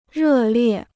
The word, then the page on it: 热烈: rè liè